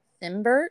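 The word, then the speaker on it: Cimber: And then she had a son with her third husband, which was film director Matt Simber. I guess you say his name is. It's C-I-M-B-E-R. I don't know. – Simber? Yeah, that sounds right.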